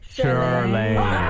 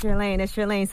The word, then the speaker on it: Chirlane: Did I say that right? – Charlaine. And I just want to tell you, I went over with him several times this morning. I was like, it's Charlaine. So make sure you say it right.